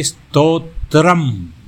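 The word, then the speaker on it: stotram (स्तोत्रम्): The strotas is actually stotram. That's what the pronunciation is.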